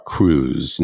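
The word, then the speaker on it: krewes: Well, for our audience, you mentioned Mardi Gras cruise. Now, cruise is spelled with a K, K-R-E-W-E-S.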